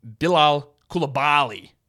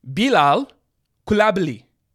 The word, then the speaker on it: Bilal Koulibaly: And let me assure you on this, that their number one draft pick, it's not pronounced Bilal Koulibaly. Koulibaly. It's not. He's French, and I've watched a lot of his games with French announcers, gone into French translation sites. It's Bilal Koulibaly. Koulibaly. Koulibaly.